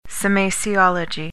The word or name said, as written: semasiology